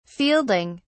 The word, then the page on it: fielding: British English